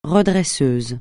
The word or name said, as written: redresseur